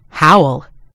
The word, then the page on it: howl: American English